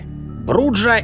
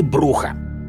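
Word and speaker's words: Brujah: Пожалуй, лучше всего о неорганизованности и анархичности этого клана говорит тот факт, что его члены не могут договориться даже как правильно произносить свое название. Бруджа или Бруха.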